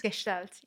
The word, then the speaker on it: Gestalt: Gestalt? – Gestalt, yes.